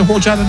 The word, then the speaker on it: horchata: Did you get a horchata the other day? – Is it horchata or horchata? – Horchata. – Okay. – Well, actually, chata, horchata. Say it fast. Nobody knows you said it wrong.